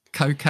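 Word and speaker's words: Koke: And Coke, I think it's pronounced, but it's spelt Coke, who plays for Seville.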